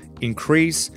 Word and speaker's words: increase: Increase, with the accent on the first syllable, is a noun and it means un incremento.